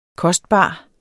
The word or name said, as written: kostbar